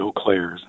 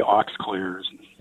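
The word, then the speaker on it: Eaux Claires: It's EAUXCLAIRES, and I pronounce it Eau Claire's, and some say Aux Claire's.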